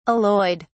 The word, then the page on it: alloyed: British English